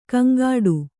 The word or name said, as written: ಗೊಂಗಟೆ